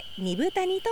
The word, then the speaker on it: 二風谷: 2 つの風の谷と書いて二分谷と読みま